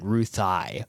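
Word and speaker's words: Ruthye: I, I heard in an interview once he he pronounced it Ruthai,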